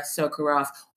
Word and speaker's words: Sukorokoff: On today's podcast, I am so happy to welcome Laura Sukorokoff. Hopefully I've pronounced your name right for today. This is my amazing friend. – Almost right, Effie. You missed a syllable.